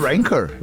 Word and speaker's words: Ranker: Como é que fala? – O Ranker.